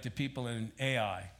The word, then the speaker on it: Ai: But when the inhabitants of Gibeon heard what Joshua had done to Jericho and Ai, not artificial intelligence. Really, we should pronounce that A-I, if it would be pronounced in Hebrew.